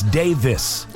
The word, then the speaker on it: Davies: He pronounces his last name as Davis, not Davies. – But it's spelled D-A-V-I-E-S? – Right. – Huh. All right. – But he says Davis.